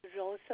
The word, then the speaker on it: Rosa: Rosa McGowan. – Can you spell that for me? – Okay. R-O-S-A.